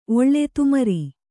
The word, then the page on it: ಒಳ್ಳೆ ತುಮರಿ: oḷḷe tumari